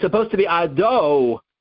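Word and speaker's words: Ado (אֲדֹנָי): He said to me, you are saying the middle syllable with a kamat instead of a cholam. Allow me to explain. He said, it's a common mistake, and the post can talk about it. You're not saying Ado. No, you're not saying that. You're saying Adah.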